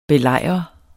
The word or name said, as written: belejrer